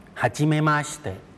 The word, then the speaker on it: はじめまして: はじめまして